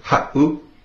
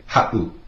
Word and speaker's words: ha7u: If you listen to that word ha'u, ha'u, it would be spelt H-A-7-U. If you were to take out that 7, it would be pronounced ha'u, with the 7 in there. It makes that little glottal stop, that pause. So you say ha'u, ha'u.